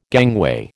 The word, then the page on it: gangway: American English